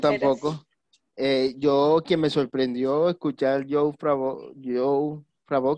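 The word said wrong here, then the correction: Jon Favreau: creo que se llama así, deja y lo busco para pronunciarlo bien. – El creador. – El creador, sí, se llama John Favreau.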